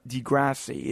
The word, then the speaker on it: Degrassi: I believe I finally said it right, so I want to give myself a tap on the shoulder. I always said Degrassi, but read Degrassi.